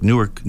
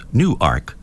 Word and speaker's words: Newark: These pronunciations, you've got to be looking up stuff here because it's not pronounced like Newark, New Jersey, they tell me. It's Newark, Delaware.